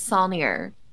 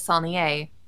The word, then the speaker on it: Solnier: The Texas pronunciation is Solnier. The proper pronunciation is Solnier, as I've been, you know, for long enough. And I think I'm the only one that says it that way in my family, because I got yelled at by a music instructor in college. who told me I was saying my last name wrong.